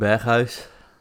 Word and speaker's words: Berghuis: Yeah, we're talking about Steven Berghuis.